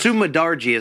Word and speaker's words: Sumudaerji: Let's go over to Sumadarji, I guess. – Sumadarji is how we will correctly pronunciate it.